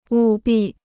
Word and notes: 务必: wù bì

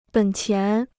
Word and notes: 本钱: běn qián

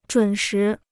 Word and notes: 准时: zhǔn shí

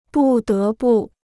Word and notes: 不得不: bù dé bù

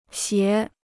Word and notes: 斜: xié

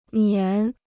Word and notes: 粘: zhān

